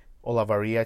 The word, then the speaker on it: Olavarria: Olavarria, it's spelled O-L-A-V-A-R-R-I-A.